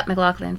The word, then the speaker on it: McLachlan: Yeah, McLachlan, for sure.